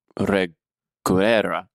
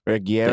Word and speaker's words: Reguera: Reguera. – Reguera.